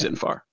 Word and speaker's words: Xinphar: it's spelled X-I-N-P-H-A-R. Okay. Zinfar.